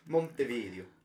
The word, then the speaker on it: Montevideo: Montevideo.